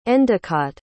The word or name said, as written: endecott